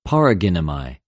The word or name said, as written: παραγινομαι